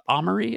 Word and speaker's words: Amaury: Amory, is that how you say his name? – Yeah.